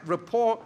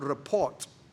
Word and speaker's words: rapport: Rapport is spelled R-A-P-P-O-R-T. It's not report. Spelled wrongly, report. That's different. It's not harbor, port. It's just rapport, meaning to say relationship developed.